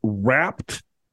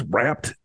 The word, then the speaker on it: rapt: What is rapt attention? – Never heard that word. – Am I saying that right? – Yes. It is rapt. – R-A-P-T.